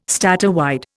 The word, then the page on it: statewide: Indian